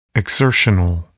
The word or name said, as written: exertional